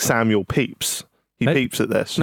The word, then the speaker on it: Pepys: His name is Pepys, but it's spelled Pepys.